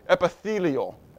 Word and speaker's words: epithelial: How do you say that? Epithelial. Epithelial.